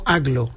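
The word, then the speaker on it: Agloe: No, entiendo que Aglo, yo lo he escuchado pronunciado como Aglo.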